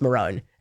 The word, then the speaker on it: maroon: You do know it is actually pronounced Marone and he is actually correct.